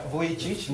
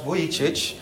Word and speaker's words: Vujicic: Yeah, Vujicic, Nicholas Vujicic. That is actually how the surname is pronounced.